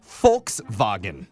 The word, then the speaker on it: Volkswagen: No, not Volks, because the V is pronounced with an F. So it's Volkswagen.